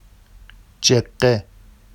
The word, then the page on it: ʤeɢɢe: Vafsi